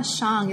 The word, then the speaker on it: Zhang: And speaking of seemingly simple issues, so Lama Zhang, is that the way you pronounce his name? – Yes, that's right.